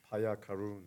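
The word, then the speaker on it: Payakaroon: How do I say his last name? – Paya Karun.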